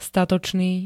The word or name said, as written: statočný